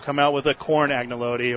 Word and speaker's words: agnolotti: Agnolotti. I'm going to pronounce it. It's agnolotti.